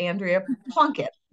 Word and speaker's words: Andrea Plunkett: So my guest today is, and I love that you have Singagram as your name, but Andrea Plunkett.